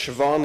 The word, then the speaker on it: Siobhan: It's pronounced Siobhan. It's an Irish name.